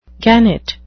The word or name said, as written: gannet